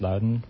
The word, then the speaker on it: London: It's spelled London. It's pronounced Loudoun.